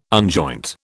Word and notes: unjoint: British English

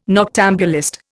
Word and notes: noctambulist: Indian

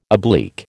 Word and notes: oblique: American English

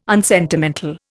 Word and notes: unsentimental: Indian

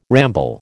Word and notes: ramble: American English

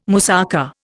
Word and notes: moussaka: Indian

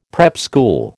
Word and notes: prep school: American English

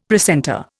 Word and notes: precentor: Indian

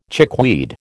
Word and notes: chickweed: American English

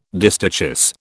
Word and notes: distichous: British English

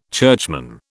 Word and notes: churchman: British English

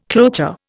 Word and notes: cloture: Indian

